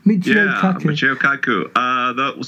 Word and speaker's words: Michio Kaku: Neil deGrasse Tyson or Michio Kaku? Is that how you pronounce it? – Michio Kaku.